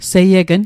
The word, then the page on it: seiegn: Mi'gmaq